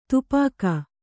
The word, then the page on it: ತುಪಾಕಿ: tupāki